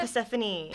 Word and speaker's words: Persephone: Oh, Persephone.